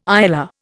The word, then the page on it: ILR: Indian